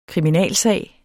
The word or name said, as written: SAGEN